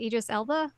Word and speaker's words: Idris Elba: It was announced that Idris Elba, is that how you say his name? – Yes.